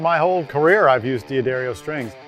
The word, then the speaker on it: D'Addario: And I say it right, D'Addario.